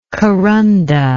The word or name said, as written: karanda